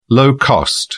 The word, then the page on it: low-cost: British English